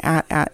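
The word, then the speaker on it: AT-AT: However, I did have a conversation very recently with someone about this, and then we Googled it, and at at is the correct pronunciation.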